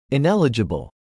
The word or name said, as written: ineligible